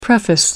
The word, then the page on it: preface: American English